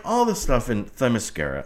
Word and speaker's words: Themyscira: Okay, so the great oracle of the internet says that it is spelled T-H-E-M, like them, Themyscira.